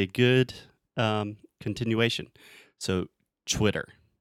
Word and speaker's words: Twitter: So the first sound, you are actually still making that ch, the ch, when I say chwi, but I'm adding the w sound at the end. So I'm making a circle with my mouth and saying Twitter.